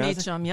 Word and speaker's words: Meacham: John Meacham, yep.